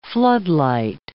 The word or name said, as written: floodlight